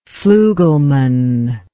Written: flugelman

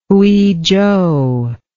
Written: Huizhou

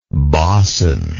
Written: bauson

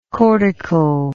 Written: cortical

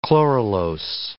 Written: chloralose

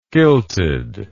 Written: kilted

